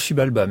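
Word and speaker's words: Xibalba: Et dans votre dernière bande dessinée, Chibalba, hein, c'est avec un X, ça se prononce ainsi, ou Chibalba, ça se prononce comment ? – Ça se prononce Chibalba, mais on peut dire un peu comme on veut.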